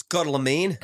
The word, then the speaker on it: Carloman: I'm pronouncing that correctly. Carloman. – It's Carloman.